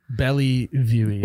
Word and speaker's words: Bellevue: Bellevue.